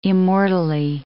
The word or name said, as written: immortally